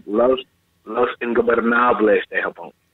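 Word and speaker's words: Los Ingobernables de Japón: Listen, Los Ingobernables de Japan. You got to roll the R's, guys. Got to roll your R's.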